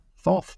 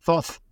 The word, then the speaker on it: Thoth: Thoth, of course, is in Greek pronunciation of tooth or tut.